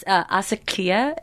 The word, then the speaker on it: Asakia: It's Asakia. It's Ugandan. – Okay. – Pronounced like it has like Tosha that Swahili.